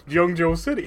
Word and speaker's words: Zhengzhou: Yes, that's how you would think it's pronounced, but no, it's Zhengzhou City. – Zhengzhou. – It's Z-H-O-N-G-Z-H-O-U.